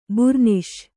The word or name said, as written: ಬೂರ್ನೀಸು